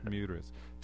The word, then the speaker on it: Miuras: And in particular, there's one strain or one variety of bulls, the Marias. Am I not pronouncing it? – Miuras.